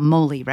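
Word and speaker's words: mōlī: It's about moli, right? Is that how you pronounce it? – That is. It's the Hawaiian word for the Laysan albatross.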